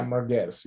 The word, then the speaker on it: merguez: Yeah, merguez, yep.